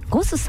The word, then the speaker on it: GOSS: ゴスさん、 GOSS と書いてね、ゴスさん、そしてお寿司屋さんが 2店舗、お魚のブリ2 門と書きましてシモンというお店が銀座に 1 店舗、日本橋に 1店舗ございます。